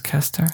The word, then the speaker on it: Kester: My last name rhymes with molester. It's Kester.